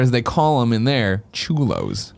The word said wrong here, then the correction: cholo: No, I'm not saying that right. Cholo.